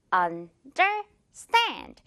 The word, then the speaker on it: understand: Understand. 이렇게 세 번으로 나눠져 있는데 마지막 부분, 이 stand 부분을 강하게 발음을 해주는 거예요.